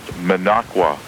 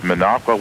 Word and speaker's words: Minocqua: The town that he spelled and couldn't pronounce is pronounced Minocqua, Wisconsin. It's an Indian word, which I don't remember what it is, but it's pronounced Minocqua, Wisconsin, and the station is on 1570 AM, 5,000 watts daytime.